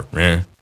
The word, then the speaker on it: Reims: and then later in Reims, or Reims, if you're pronouncing it in French.